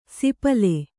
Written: ಸಿಪಲೆ

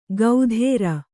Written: ಗೌಧೇರ

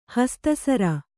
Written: ಹಸ್ತಸರ

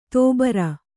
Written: ದುಬಾರಿ

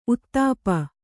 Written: ಊತಪ್ಪ

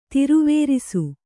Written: ತಿರುವೇರಿಸು

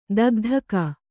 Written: ಥಕಥಾಕು

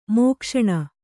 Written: ಮೋಕ್ಷಣ

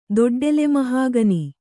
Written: ದೊಡ್ಡೆಲೆಮಹಾಗನಿ

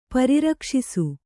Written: ಪರಿರಕ್ಷಿಸು

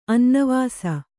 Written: ಅನ್ನವಾಸ

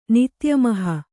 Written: ನಿತ್ಯಮಹ

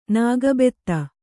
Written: ನಾಗಬೆತ್ತ

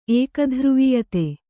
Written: ಏಕಧ್ರುವೀಯತೆ

